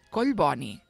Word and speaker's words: Collboni: I per què hi ha gent que diu Collboni? – perquè no saben una norma bàsica de la pronunciació catalana, que és que els mots compostos es pronuncien amb doble accent.